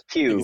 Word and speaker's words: Pugh: and I hope we get to see Florence Pugh again, or Puff, or Pug, or what, I don't know how to say it. It's P-U-G-H. – I think it's Pew. It's Pew? Okay. – Pew.